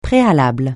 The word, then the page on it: préalable: francais